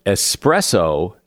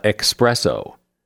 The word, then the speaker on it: espresso: Espresso. Strong coffee in a tiny cup has no X in it, either in writing or pronunciation. It is espresso, not expresso.